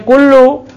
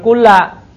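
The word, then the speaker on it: kullu (كُلُّ): Nah, makanya dibacanya kullu bukan kulla, ya.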